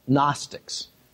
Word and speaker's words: Gnostics: There were, in the first and second centuries, people called Gnostics, a silent G in the front, spelled G-N-O-S-T-I-C-S in the plural.